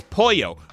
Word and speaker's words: pollo: He goes, what's in your polo burritos? We're like, Roy, it's pollo.